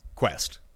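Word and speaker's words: Kuest: What is the actual pronunciation? Not Quest. That it'll matter, because he's always Peter Cust. – Quest. – Quest. Okay, yeah. A Galaxy Quest.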